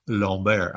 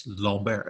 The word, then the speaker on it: Lambert: And so I asked my neighbor, who's French, I was like, how do you pronounce this name? And he's like, Lambert. I was like, Lambert? And he's like, yes, Lambert.